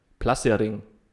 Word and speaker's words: placering: Schwedisch, dann ist es Placering. Wie? Platzering oder Plassering. Ich würde es Plassering aussprechen. Plassering.